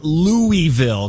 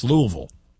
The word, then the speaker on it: Louisville: So just teaching you today, if I can teach you one thing, it's not Louisville because they hate that. It's Louisville.